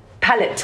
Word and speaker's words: palette: It's not palace. How do you say palette? – Palette. Yeah. Sorry.